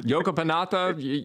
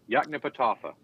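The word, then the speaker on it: Yoknapatawpha: Yokopanatha. – Yaknepatawpha.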